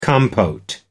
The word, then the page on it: compote: American English